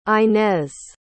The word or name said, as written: inez